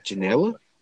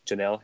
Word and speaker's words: Janela: It's like Gelada or Gelay. – Janela? – Janela, yeah.